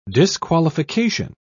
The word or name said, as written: disqualification